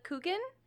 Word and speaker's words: Coogan: I am the What's Up associate editor, and we're so excited to have Lila Coogan.